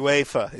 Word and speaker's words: UEFA: UEFA is the correct pronunciation.